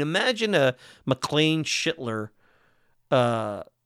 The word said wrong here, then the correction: Schlittler: Am I going to have to get bleeped on Cam Schittler? Schlittler.